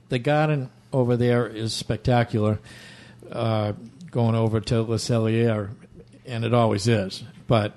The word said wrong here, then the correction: Le Cellier: the R is silent. Le Cellier. – Le Cellier.